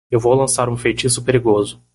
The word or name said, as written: feitiço